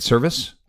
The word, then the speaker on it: Servais: Scott Servais, is that how you say it? Service? – Yeah, it's a service. Yep. – Service, sorry, it's spelled Servais, but service.